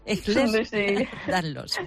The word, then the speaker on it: Ehlers-Danlos: Ella es la delegada de ANESED, la Asociación Nacional del Síndrome de Ehlers, madre mía, si es que no lo digo bien, Ehlers-Danlos e hiperlaxitud en Aragón. ¿qué tal? Muy buenos días. – Buenos días. – Es que no es fácil el nombrecito, ¿eh? – Ehlers-Danlos.